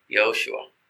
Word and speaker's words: Yehoshua: Sometimes we hear it said Yehoshua, but like normally it would be pronounced a little bit faster than the broken way I might do it. So it sounds more like Yehoshua, which is very close to Yeshua, right?